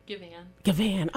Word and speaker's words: Gavahn: Gavahn. – Oh, I put it the emphasis on the wrong syllable, but forgive me.